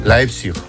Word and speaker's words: Leipzig: ¿Cómo se dice? – Leipzig. – Leipzig. – Leipzig. – ¿Ves? – Leipzig. Muy bien.